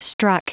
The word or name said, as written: struck